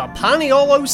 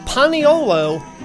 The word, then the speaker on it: paniolo: Experts in Hawaiian etymology believe Paniolo is the Hawaiianized pronunciation of the Espanol, The Hawaiian language has no S sound, and all syllables and words must end in a vowel.